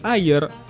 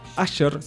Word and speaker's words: Ayer: un norteamericano que se llama David Ayer, Asher, se escribe A como ayer, A-Y-E-R,